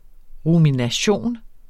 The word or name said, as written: rumination